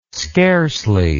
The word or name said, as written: scarcely